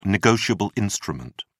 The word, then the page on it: negotiable instrument: British English